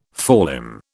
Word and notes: forelimb: British English

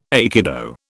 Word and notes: aikido: British English

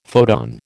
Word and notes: photon: American English

